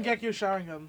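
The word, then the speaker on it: Mangekyō Sharingan: Because he's going to prove to Itachi that he doesn't need to kill to get the Majenko Sharingan. – Like Frank Sinatra. – Eventually, I'll learn how to say that phrase correctly. Mangekyo Sharingan.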